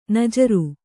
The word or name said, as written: ನಾಜರು